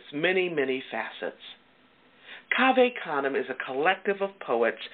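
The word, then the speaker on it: Cave Canem: Kaveh Kanem. It's spelled C-A-V-E, like cave, okay? Kanem, C-A-N-E-M.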